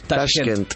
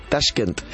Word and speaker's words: Tashkent: Tashkent. – Tashkent, entre muchas risotadas y golpes en los muslos.